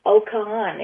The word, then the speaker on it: Oka'an: The resort that we were staying at was called Okan, and it's spelled OKA'AN. Okan. It's the way the locals or the Mayans used to pronounce it.